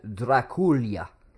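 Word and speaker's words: Drăculea: He also went by probably his best-known name, Vlad Draculia, is how it's actually pronounced. – That's familiar. – The Romanian way to pronounce it.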